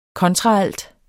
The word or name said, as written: kontraalt